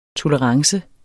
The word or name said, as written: tolerance